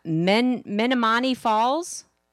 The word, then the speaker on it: Menomonee Falls: So, how do you say that? Menomonee Falls? – That's close enough.